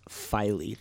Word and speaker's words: Philae: Okay, the reason he's having so much trouble is because you look at the word, it does not look like it would be pronounced Philae. There's an A in the last syllable.